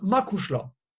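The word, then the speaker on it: mo chuisle: Et dans ses combats, avant des grands combats notamment, il va lui offrir un peignoir de boxeur où il y aura écrit quelque chose en irlandais dans le dos, que tout le monde prononce Makushla.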